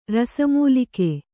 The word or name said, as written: ರಸಮೂಲಿಕೆ